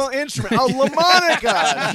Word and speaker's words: Lamonica: It rhymes with a musical instrument. – A LaMonica, sure.